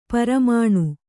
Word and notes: ಪೆರ್ಮನೆ: Kannada

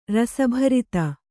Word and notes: ರಸಭರಿತ: rasa bharita

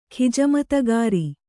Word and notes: ಖಿಜಮತಗಾರ: khijamatagāra

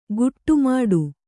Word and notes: ಗುಟ್ಟುಮಾಡು: guṭṭu māḍu

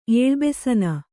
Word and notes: ಏಳುಬೆಸನ: ēḷubesana

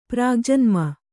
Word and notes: ಪ್ರಾಗ್ಜನ್ಮ: kannada

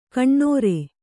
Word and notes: ಕಣ್ಣುರಿ: kaṇṇuri

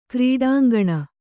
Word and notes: ಕ್ರೀಡಾಂಗಣ: krīḍāŋgaṇa

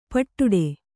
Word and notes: ಪಿಡ್ಡ: piḍḍa

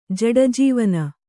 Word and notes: ಜಡಜೀವನ: kannada

